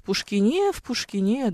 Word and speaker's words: Пушкине: Ударение тогда в Пушкине, говорит Александр Фельдман. В Пушкине, да, что-то в этом стиле.